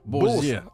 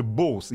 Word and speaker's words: Bose: Бозе. Чем многие говорят Бозе? – Нет, на самом деле, если говорить правильно, то это, конечно же, Боус.